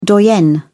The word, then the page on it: doyenne: British English